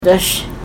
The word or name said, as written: dash